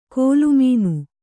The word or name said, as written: ಕೋಲುಮೀನು